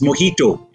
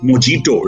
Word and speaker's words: mojito: Similarly, when people try to pronounce slightly complicated words like a drink, which is mojito, it's not mojito, right?